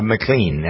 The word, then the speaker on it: McLean: Jeffrey said Lee Greenwell, he's got two, so it's sort of a tie with Jeffrey Goad, G-O-A-D is the last name. He says American Pie, Don McLean, McLean actually is how he pronounced his name, Canadian, even though he sang American Pie, bye, you know that song.